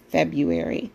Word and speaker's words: February: With that R, I don't know why anybody nobody pronounces it that I know of with the R right there, February. But obviously we're saying it wrong.